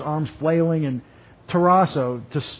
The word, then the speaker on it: tarasso: I want to look at this word, tarasso. T-A-R-A-S-S-O. Long O. Tarasso.